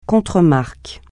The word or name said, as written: contremarque